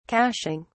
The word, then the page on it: cashing: British English